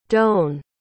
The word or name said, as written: doane